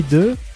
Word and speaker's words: œufs: Alors, est-ce qu'ils se livrent à des batailles d'eau, à des batailles d'œufs, hein, mais au pluriel, normalement, on prononce pas le F, ou à des batailles de farine? – Ou des batailles de vodka.